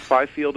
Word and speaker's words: Fifield: Is it Fifield? Is that how you say it? So it's like F-I Field, like mm-hmm. a field of grass or whatever.